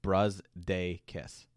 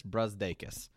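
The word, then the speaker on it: Brazdeikis: Basketball Reference has the phonetic spelling as Ignas Brusdakis. So it's Brusdakis. So Ignas Brusdakis.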